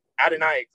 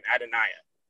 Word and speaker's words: Adoniah: But as far as players go, Ivy and in Brohm both mentioned Adoniah Green is how you pronounce his name, Adoniah. So think about Addo, and then the like, break it into two. So Naya, like the J is like a I.